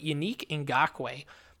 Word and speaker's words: Yannick Ngakoue: It took me a little bit to learn how to pronounce his name, but Yannick Ngakoue, the defensive end for the Jacksonville Jaguars, very unhappy with his situation there.